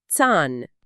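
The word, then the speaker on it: Zahn: لا، هو في الألماني دايماً وأبداً تي، فكلمة سن زان لازم تتنطق تان.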